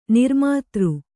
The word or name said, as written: ನಿರ್ಮಿತ್ರ